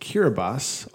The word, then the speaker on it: Kiribati: It is pronounced Kiribati, although it is spelled Kiribati.